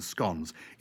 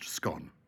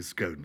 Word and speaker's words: scone: I have to tell you, the scones, it's correctly pronounced scone. there are people who say scone still. No, no, scone.